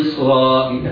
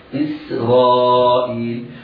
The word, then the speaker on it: إسرائيل: السين في إسرائيل حرف مرقق بجانب الراء المفخمة، فقد يسبق اللسان وينطقها المبتدئ في القراءة صاد أو قريبة من الصاد، هذا خطأ، على القارئ أن يهتم بإظهار كسرة همزة إسرائيل وترقيق السين هكذا. يا بني إسرائيل، قد يسبق اللسان فيقول يا بني إسرائيل، هذا خطأ، أنا لا أبرؤ على إكمال الكلمة لهذا الخطأ، وإنما ننطقها سينا مرققة، يا بني إسرائيل، بعدها راء مفتوحة حكمها التفخيم، بعدها ألف، بعدها همزة مكسورة، إذا هو مد متصل يمد بمقدار أربع حركات.